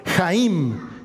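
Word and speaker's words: Jaim (חיים): Y ya hemos visto las dos primeras puertas y hoy nos toca ver la tercera, que en hebreo se pronuncia Jaim y que significa la vida.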